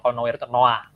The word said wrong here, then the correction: Noir: Noah, Noir. Noir. Oh, iya, iya. Noir. Noir. Gitu.